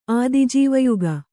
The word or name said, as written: ಆದಿಜೀವಯುಗ